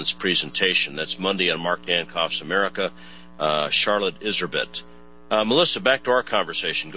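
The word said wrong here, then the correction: Iserbyt: Yes, well, very gently I would correct you on her pronunciation of her name. It's Iserby. – Is it Iserby?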